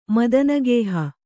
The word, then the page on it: ಮದನಗೇಹ: Kannada